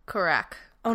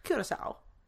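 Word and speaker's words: Curaçao: Kirakau. – Nope. – Kirako. Kirasu. – Curacao.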